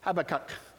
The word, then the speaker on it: Habakkuk: Go backwards 4 books and you'll be to the book of Habakkuk. Or as I've heard some pronounce it, Habakkuk. So, but Habakkuk. Either way is fine.